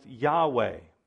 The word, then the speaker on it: Yahweh: They added an A and they added an E. And so this way it could be pronounced Yahweh.